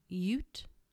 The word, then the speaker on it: Ute: But the hotel's ghost reputation is also attributed to the spirits of the area's Ute. I did look that up earlier. That is how they pronounced it on the internet.